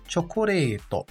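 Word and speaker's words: chocoreto (チョコレート): Quanto à pronúncia, note uma contração da letra yo com a letra ti, tornando-se cho. E também há um prolongamento do som do re, que é indicado pelo traço. Logo, chocoreto. Esta palavra muitas vezes é encurtada para apenas choco.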